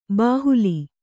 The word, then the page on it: ಬಾಹುಲಿ: Kannada